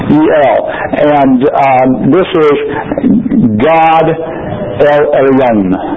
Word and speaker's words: Elyon: This is the way I pronounce it. There are probably two or three different pronunciations, and if I spoke Hebrew at any worth, it would probably sound a little different. I always have a heavy accent. I know when I speak Spanish, my wife about dies because of my accent, so this is my English version of the Hebrew word, pronounced Elyon.